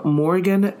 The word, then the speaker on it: Morgan: Savilla is spelled C-I, V as in Victor, I-L-L-A. That's C as in Charlie, I, V as in Victor, I-L-L-A. Last name Morgan, M-O-R-G-A-N.